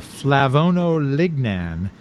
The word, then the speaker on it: flavonolignan: And you could probably pronounce this one a lot better than I can, the flavonolignan.